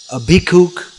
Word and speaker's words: bhikkhu: the pronunciation of ksha is ka, as is still in Bengal, and in so Maithili, that area of India, so bhikshu became bhikkhu.